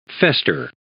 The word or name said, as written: fester